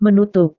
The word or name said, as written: menutup